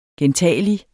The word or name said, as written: gentagelig